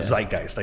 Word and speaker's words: zeitgeist: a modern zeitgeist, or, yeah, zeitgeist, I guess it's pronounced, uh-huh, zeitgeist. Uh-huh. I'm not good at German.